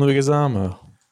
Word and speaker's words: Leguizamo: I'm going to butcher his last name if I try it out right now, but it's Luigi, John Leguizamo.